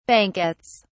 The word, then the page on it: banquettes: British English